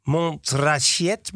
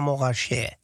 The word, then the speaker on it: Montrachet: Het is niet Montrachet, maar Morachet.